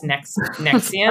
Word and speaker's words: NXIVM: If you want to look it up, it's NXIVM, but pronounced Nexium.